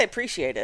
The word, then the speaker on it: appreciate: I say appreciate it. Like like it's two syllables, like pre-shate.